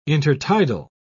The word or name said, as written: intertidal